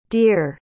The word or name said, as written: deare